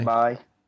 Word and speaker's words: Bai: Bay or Bai? B-I? – Bai.